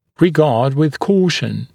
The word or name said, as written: regard with caution